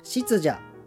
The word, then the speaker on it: 湿邪: 特に梅雨時期の不調は湿気の邪と書いて湿邪と読み